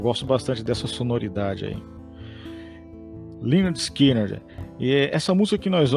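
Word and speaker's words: Lynyrd Skynyrd: o título do álbum é exatamente pronúncia Leonard Skinner.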